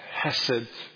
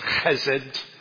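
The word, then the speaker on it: chesed (חסד): He said it's called hesed. Actually, it's chesed. But he didn't want to spray everybody on the front row. So he didn't pronounce that guttural sound.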